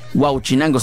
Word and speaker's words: Huauchinango: Huauchinango, sí, lo dije bien. Porque una vez lo pronuncié mal, como si lo pronuncié como si fuera el pescado.